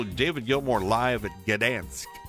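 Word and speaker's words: Gdańsk: How do you pronounce that town, that city in Poland? It's spelled G-D-A-N-S-K.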